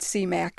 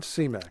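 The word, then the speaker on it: Simak: Clifford C. Mack. – Clifford C. Mack.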